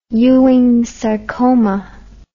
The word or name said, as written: Ewing's sarcoma